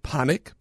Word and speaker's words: Panik: Panik? – Yeah.